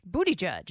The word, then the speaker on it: Buttigieg: Thank you for saying it right. – I know. Pete Buttigieg? – Buttigieg. Yeah. That's how you say it. That's how it's said.